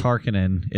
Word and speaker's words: Harkonnen: House Harkonnen, the bad guys. I think it's in the background... I can't exactly remember. – I think it's Harkonnen. – It's Harkonnen in the audiobook.